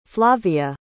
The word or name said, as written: flavia